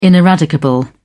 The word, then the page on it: ineradicable: British English